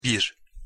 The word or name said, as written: bir